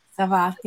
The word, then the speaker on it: Savah: She is also the founder of Savah. Am I saying that right? – Savah, yeah.